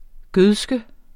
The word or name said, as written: gødske